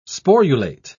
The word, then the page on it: sporulate: British English